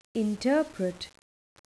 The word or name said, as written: interpret